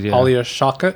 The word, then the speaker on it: Alia Shawkat: Alia Shawkat.